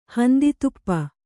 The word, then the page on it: ಹಂದಿತುಪ್ಪ: handi tuppa